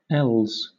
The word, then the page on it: els: British English